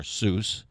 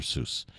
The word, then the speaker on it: Seuss: Anyway, today, like I said, we're gonna go over some things about that were written by Dr. Seuss, or Seuss. Depends on who you are. Some people pronounce it like a Z, and some people pronounce it like an S. I'm going to pronounce it like an S, Dr. Seuss.